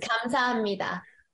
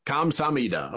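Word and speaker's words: Kamsahamnida (감사합니다): And so with that, I think it's Kaham Sumida. Is that? – It's Kamsahamnida. – Kamsahamnida. Okay. I butchered it a little bit.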